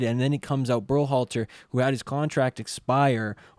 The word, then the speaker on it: Berhalter: Greg Berhalter.